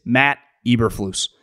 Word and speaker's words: Eberflus: His name is Matt Eberflus.